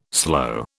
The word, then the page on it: sloe: British English